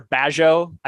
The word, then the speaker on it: Bagehot: A lot of stuff on Walter Bagehot, I think is how you say his name. B-A-G-E-H-O-T.